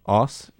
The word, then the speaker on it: Oss: Rob from, I guess it's pronounced Oss in the Netherlands. – How do you spell it? – O-S-S.